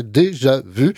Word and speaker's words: Déjà Vu: elle s'écrit d'ailleurs avec les accents comme en français, Déjà Vu, ça se prononce comment à la française.